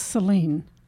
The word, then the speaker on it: Saline: The county is Saline. – That's probably my typo. – Oh, okay. So it's Salina. So it's Saline County? – Saline County. – All right, Saline County.